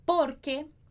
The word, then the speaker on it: porque: Porque. Porque. Então, essa parte mais forte é no por. Porque. Es más abierta. Abierta. A palavra é aberta, mas o E é fechado, abierta, abierta. Porque es más abierta en la extremidad.